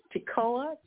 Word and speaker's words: Tekoa: And I think pronunciation where he came from is Tekoa, T-E-K-O-A. Am I pronouncing that right? – Mm-hmm.